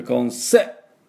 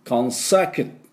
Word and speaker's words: consecutive: で、 consecutive。にアクセントがあります。だから consecutive、 cute の cu、 cu は cute と同じですね、 cute の cutive だから consecutive、 consecutive っていうような感じになります。